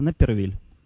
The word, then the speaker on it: Напервиль: А, город наш называется Напервиль, и по-русски он так и пишется, как и слышится, Напервиль. Ну, по-английски Напервиль он.